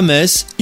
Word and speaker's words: Metz: Toutefois, à Metz, il faut savoir que la règle est effective depuis une centaine d'années seulement. Car si les origines de Metz sont latines, cette histoire de prononciation date de l'annexion allemande en 1871 et 1918. Une époque où le T et le Z se prononçaient encore avant que les annexés ne décident de les ignorer.